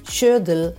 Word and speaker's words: Schödl: S-C-H-O coi puntini dell'umlaut sopra, come si dice in tedesco per indicare la metafonia, poi D-L. Schödel,